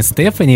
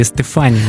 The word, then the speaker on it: Стефани: Если на итальянский манер, то правильно, конечно, Стефани. А по-английски ударение всегда ставится на первый слог. Будет, соответственно, Стефани. Если хотите кто-то по-французски назвать, надо говорить Стефани. У них-то последний слог ударения ставится.